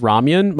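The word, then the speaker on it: ramyeon: I thought it was pronounced ramyun my entire life until I realized, I'm like, no, that is how it's pronounced if you're Korean.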